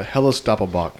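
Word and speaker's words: Helles Doppelbock: Is that how you say it? Helles Doppelbach. – Yeah, Helles Doppelbach.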